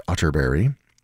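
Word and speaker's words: Atterberg: It's spelled Otterberg, but pronounced Otterberg. I have it on good authority.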